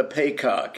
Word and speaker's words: Paycock: It's called Juno and the Paycock. It's an Irish play. It's an Irish pronunciation of peacock.